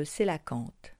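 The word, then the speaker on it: Coelacanthe: Rapprochez-vous du panneau sur le Sélacanthe, qui s'écrit C, O, E dans le O, L, A, C, A, N, T, H, E.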